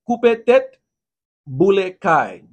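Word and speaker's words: Koupe tèt, boule kay: Bullet. No, I'm sorry. I'm about to say it wrong. I'm sorry. Coupe tête, bullet caille.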